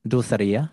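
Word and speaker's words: Dulcería: Am I saying it right? Dulceria? Is it Dulceria? – Dulceria. – Dulceria. 50-50 shot. And I went the wrong way. – Casi, no, es casi, Dulceria. Dulceria. You just put the accent in the wrong place.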